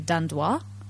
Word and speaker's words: Dandoy: No, Dandoi is 元々 from Belgium. – Oh, okay. – Yeah. So I mean, in English, 今は it's spelled D-A-N-D-O-Y with a Y at the end, but it was originally with an I at the end. – Oh, okay. – The French pronunciation would be Dandois.